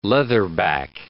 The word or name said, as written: leatherback